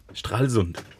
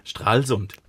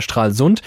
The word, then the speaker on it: Stralsund: Strahlsund. Nein, die Betonung liegt auf Strahl und nicht auf Sund. Strahlsund. Nein, die, die Betonung liegt auf Strahl, nicht auf Sund.